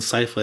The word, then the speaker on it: Sypha: It's, it's It's pronounced Sypha in the yeah. cartoon.